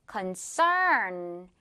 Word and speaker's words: concern: 근심, 우려 이런 뜻인데, 자, 발음은 뒷부분에 강세 주면서 earn 발음해 주면 돼요. concern, concern. 오케이? 자, 이거 예문 한번 볼게요.